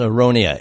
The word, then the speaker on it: aronia: I got a handful of aronia berries here. Did I say that right there? – Yes, aronia. A-R-O-N-I-A.